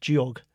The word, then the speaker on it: Georg: Like, my name, you know, if we're going to be pedantic, my name is pronounced Georg.